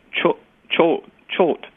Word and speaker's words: Choate: I would like to draw your attention to an author I saw yesterday on C-SPAN, and I think the name is pronounced Choate, C-H-O-A-T-E.